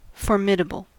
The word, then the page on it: formidable: American English